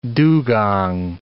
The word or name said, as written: dugong